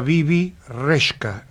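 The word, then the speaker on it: Bebe Rexha: Y después tenemos a Bibi Reshka. – Exacto. Sí, Reshka. Viste, pero te puse la pronunciación como se dice para que lo digas bien.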